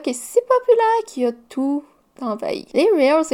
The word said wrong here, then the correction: Reels: La manière que j'ai prononcé ça m'insulte tellement. Ce sont des Reels et des Shorts pas des real short, sont la version hors retard des TikTok.